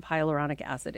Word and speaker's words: hyaluronic acid: I want to know in particular about hyaluronic acid.